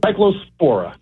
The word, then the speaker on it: cyclospora: It's cyclospora, and it's a parasite.